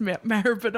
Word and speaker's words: Maribyrnong: But I finally made it out to Maribyrnong. – Good girl, good pronunciation. The Sydney girl pronounced Maribyrnong.